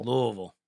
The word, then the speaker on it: Louisville: Yeah, it's like saying Louisville, you know. Most people say Louisville. It's Louisville. Louisville. – Louisville. – Louisville.